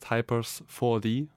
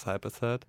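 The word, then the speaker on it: Hypers4d: It's a 4 instead of an A. A lot of people say just Hypers 4D, like it's like a 3D movie or something. But I would like it to be pronounced just as Hyper Sad.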